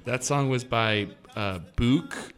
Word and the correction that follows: Buck: Say it as if it rhymes with duck. – Duck. Buck.